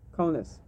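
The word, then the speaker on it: Kaunas: Konas. – Is that the Lithuanian pronunciation? – Yeah.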